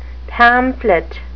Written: pamphlet

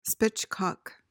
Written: spitchcock

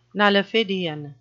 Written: nullifidian

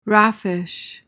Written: raffish

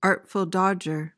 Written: artful dodger

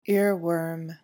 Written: earworm

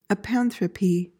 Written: apanthropy

